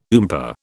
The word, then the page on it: oompah: British English